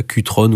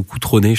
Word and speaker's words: Cutrone: Je me semble que ça se dit comme ça, Cutrone ou Coutronné. Je pense que c'est plus Coutronné si on le prononce bien.